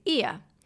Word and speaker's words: Oia: Ia. So Ia is the kind of famous sunset Instagram spot that you've seen where everyone talks about a Santorini sunset. That's in Ia, and it's spelled O-I-A.